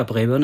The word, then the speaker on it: Braeburn: Ja, Braeburn ist schon richtig ausgesprochen.